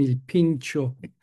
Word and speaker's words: Il Pincio: Il Pincio, to pronounce it in Italian, in Rome.